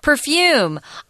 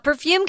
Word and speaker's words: perfume: Perfume. Perfume can be perfume, where the accent is on the first syllable or the last.